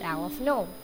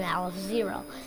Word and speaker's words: Aleph-Null: The symbol is often pronounced Aleph-Null rather than Aleph-0, Probably because null is the word for zero in Georg Cantor's native language of German.